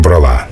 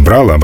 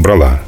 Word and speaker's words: брала: особенно в прошедшее время, ударение тяготеет к концу. Например, «брала». Не «брала», а «брать», но «брала».